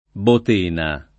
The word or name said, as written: Botena